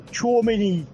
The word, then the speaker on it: Tchouaméni: Aurélien Tchouaméni, Paris Saint-Germain. Buenas noches. – Buenas noches, qué bien lo pronuncia, ¿eh? Es un francófono total, parece Melchor.